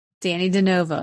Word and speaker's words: Dannie De Novo: Dannie De Novo.